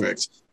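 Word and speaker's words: Koonce: It's K-O-O-N-C-E. – Perfect. – Koontz. Yes. Pronounced right, like well, the author, Dean Koontz. I don't know if you're familiar with him, but that's how it's pronounced.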